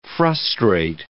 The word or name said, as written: frustrate